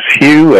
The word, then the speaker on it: Hugh: It would be my name, which is Hugh, H-U-G-H. Charlson is spelled T like Tom, R-A-U-L-S-E-N like Nancy,